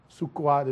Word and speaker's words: Sukkot: Sukkot is actually how they would pronounce it, but we call it Tabernacles.